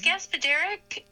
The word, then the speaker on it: Gaspodarek: Yes, it's Gaspodarek. – Gaspodarek, and I actually knew that because it said the accent's on the third syllable.